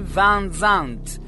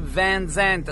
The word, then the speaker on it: Van Zant: Ipotesi A: Sweet Home Alabama era il nome della pasticceria della madre del cantante Ronnie Van Zant, o Ronnie Van Zant a seconda delle pronunce, che, volendo omaggiare la madre, scrisse appunto la canzone in questione.